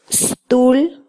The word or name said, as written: stul